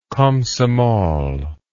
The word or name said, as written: comsomol